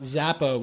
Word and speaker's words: Xapo: not Xapo, it's pronounced Zappo, we think, that has to do with Bitcoin.